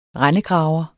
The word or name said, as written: rendegraver